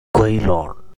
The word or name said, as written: Quilon